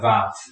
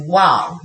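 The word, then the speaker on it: vav (וָו): I think that the Hebrew of today, they tend to pronounce like vav. – 那这个在希伯来文就是现在一般来是把它念成 vav,、就是 vav 这样子。 – Yeah, 哦，但是在那个他们神学院就是都把它念成比较像 wow, 那不晓得是不是因为他们在假设当时的希伯来文的念法是。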